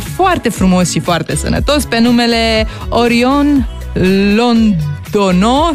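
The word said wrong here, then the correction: Londoño: sper că am pronunțat corect. – Londonio. – Londonio, merci Gomez. Mulțumesc. – Gomez. – Deci pronunță-i tu numele, te rog. – Orion Londonio Gomez.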